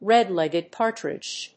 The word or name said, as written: red-legged partridge